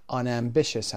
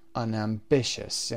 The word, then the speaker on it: unambitious: همینجا نکته رو بگم خدمتون، شاید قبلا هم کار کردیم، اگر صفتی بخواد پیشوند بگیره و دوباره بخواید اون رو با حالت بعدیش تلفظ بکنید، هیچ تغییری از لحاظ استرس پیدا نمی‌کنه. ambitious، unambitious، یعنی تغییری شما نخواهید داشت.